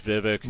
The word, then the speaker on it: Vivek: It's Vivek.